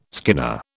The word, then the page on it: skinner: British English